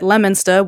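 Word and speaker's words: Leominster: Can't go past Worcester without saying Worcester, and don't forget Lemonster, which is spelled nothing like how you'd pronounce it.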